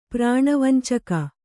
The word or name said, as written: ಪ್ರಾಣವಂಚಕ